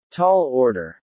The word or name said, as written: tall order